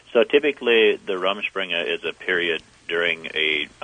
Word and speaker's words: Rumspringa: So now, tell us about Rumspringa. Did I say that right? – Rumspringa.